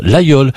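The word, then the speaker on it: Laguiole: Ça s'écrit l'Aguiole, mais on prononce l'Aïeul.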